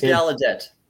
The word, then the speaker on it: Gallaudet: And pronouncing your name correctly, it's Gallaudet. – Gallaudet. Yep.